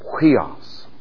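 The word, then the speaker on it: huios (υἱός): U-I-O-S. And above the top of the U, make an apostrophe. Just a little apostrophe. And it's pronounced huios. Huios.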